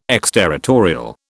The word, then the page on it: exterritorial: British English